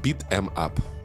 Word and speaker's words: beat em up: А, правильно говорить beat em up.